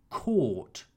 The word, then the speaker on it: caught: Catch in the simple past tense is pronounced caught. I caught the bus.